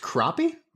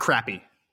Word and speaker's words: crappie: having never heard it out loud before, it's pronounced crappie? – Crappie. Yeah, not crappy. It's spelled like crappy.